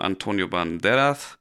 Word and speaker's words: Antonio Banderas: Ähm, Im zweiten Film wurde der gestiefelte Kater erstmals eingeführt, gesprochen von Antonio Banderas. So spricht man den aus.